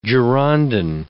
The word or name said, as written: Girondin